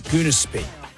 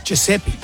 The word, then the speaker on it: Giuseppe: Because she'd never pronounced his name. – She'd just seen his name tag. – Yeah. – What was it? – Giuseppe.